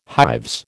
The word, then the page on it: hives: American English